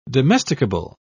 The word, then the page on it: domesticable: British English